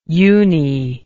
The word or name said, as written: uni